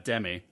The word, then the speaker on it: Demme: Demme, I believe. – Demme? – Is the pronunciation.